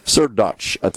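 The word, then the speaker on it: Srdoc: Natasha Sherdock. Sirdotch, is the proper pronunciation.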